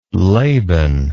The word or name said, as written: laban